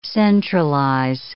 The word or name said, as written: centralize